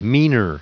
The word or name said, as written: meaner